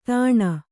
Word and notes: ದಾಣೆ: Kannada

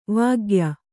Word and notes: ವಾಗ್ಯ: vāgya

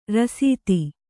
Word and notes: ರಾಸ್ತಿ: rāsti

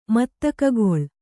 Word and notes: ಮತ್ತಕೋಕಿಲ: matta kōkila

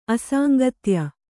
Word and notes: ಅಸಾಂಗತ್ಯ: asāŋgatya

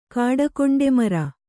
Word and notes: ಕಾಡಕೊಂಡೆಮರ: kāḍakonde mara

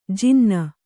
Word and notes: ಜನಾನಾ: janānā

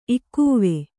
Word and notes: ಇಕ್ಕೂವೆ: kannada

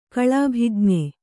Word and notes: ಕಳಾಭಿಜ್ಞ: kaḷābhiñpa

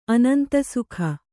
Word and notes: ಅನಂತಸುಖ: anantasukha

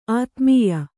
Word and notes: ಅಧೋಮಾಯೆ: adhōmāye

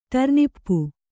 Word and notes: ಟರ್ನಿಪ್ಪು: ṭarnippu

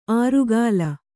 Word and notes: ಆರುಗಾಲ: ārugāla

